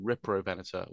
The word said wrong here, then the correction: Riparovenator: where it should be Riparovenator or Riparovenator.